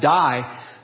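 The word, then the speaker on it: die (δεῖ): Every time Jesus uses the word must, the word in the New Testament for must, it's DEI in the Greek language. It's pronounced die, but it means three things.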